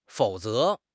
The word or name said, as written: fou3 ze2